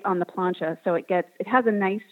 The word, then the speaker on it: plancha: And then talk a little bit about the plancha bread. Is that how you pronounce it? Okay, yeah. Yes, plancha bread.